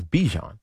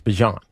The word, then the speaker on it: Bijan: Because everybody says Bijan. And he says it's Bijan. And I'm like, all right, but why does everybody call you Bijan?